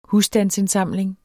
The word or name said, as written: husstandsindsamling